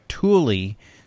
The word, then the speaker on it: Thule: The bike rack company of the same name goes by Thule.